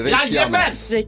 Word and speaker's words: Reykjanes: Det är svårt med isländskt uttal, så du får rätt för det. Reykjanes.